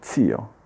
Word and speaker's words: zio: Spesso dico zio, invece è zio, mio zio, mio zio, mia zia.